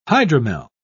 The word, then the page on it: hydromel: British English